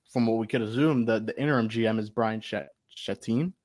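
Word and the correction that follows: Chattin: So I butchered that, didn't I, Eli? – I said his name just a few minutes ago. – Brian, I know, I forgot. Chadden. All right, Brian Chadden.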